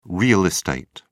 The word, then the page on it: real estate: British English